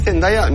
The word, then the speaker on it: Zendaya: ¿Se dice Zendaya? No se dice Zendaya. ¿Tu nombre está bien dicho? – Zendaya. – Zendaya. – Zendaya. – Vale, mejor. Venga, Pablo, tú puedes.